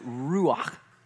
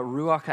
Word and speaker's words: ruach: And it is ruach. And I got a little on the microphone. I'm just kidding, I didn't. But it is ruach. And what ruach actually means,